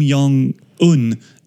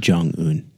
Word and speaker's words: Jong-un: It's Kim Jong-un. You have Kim problem. Jong-un. Kim Jong-un. – I think it's a soft J. Like yogging.